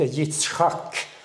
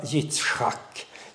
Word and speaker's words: Yitzchak: Han log, det heter Yitzchak, och därför fick sonen heta Yitzchak. Det är det hebreiska ordet som vi uttalar Isak.